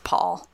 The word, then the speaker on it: Pol: When you reach the creepy little thing's lair, you run into Paul, spelled P-O-L, so that way it's a little bit more elfy, but pronounced Paul.